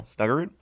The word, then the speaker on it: Snuggerud: Jimmy's, how do you say that? Snuggerud? – There you go. – Snuggerud.